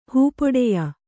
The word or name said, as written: ಹೂಪೊಡೆಯ